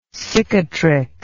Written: cicatricose

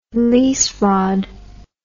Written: lease rod

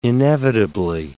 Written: inevitably

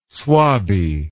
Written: swabby

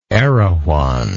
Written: erewhon

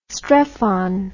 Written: strephon

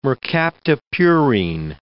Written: mercaptopurine

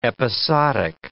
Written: episodic

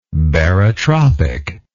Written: barotropic

